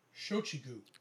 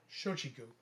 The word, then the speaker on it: Shochiku: It was actually by a company called, if I can pronounce this right, Shakua? I might be pronouncing that wrong. – Let me see. Shochiku. – Okay. – Yeah, Shochiku.